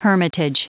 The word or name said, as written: hermitage